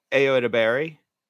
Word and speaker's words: Ayo Edebiri: I think you said it right, Ayo Edebiri.